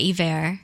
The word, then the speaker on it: Iver: How do you spell that, Iver? – I-V-E-R. – Oh, like Bon Iver. Iver.